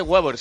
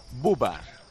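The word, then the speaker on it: Wöber: Que el nuevo fichaje del Sevilla es austriaco y que no se dice Weber, se dice Bubar.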